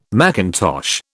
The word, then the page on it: macintosh: British English